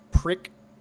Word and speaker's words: PRC: I spoke into the model PRC radio, pronounced prick.